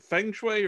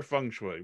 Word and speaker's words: feng shui: So I'm curious, how do you pronounce it? Is it feng shui or feng shui? Which is the best way to pronounce it? – Well, either way works. So whatever works best with your tongue.